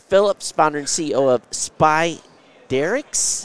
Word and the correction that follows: Spiderisk: How do you pronounce that? – Yeah, it's a tricky one. It's Spiderisk. – Spiderisk.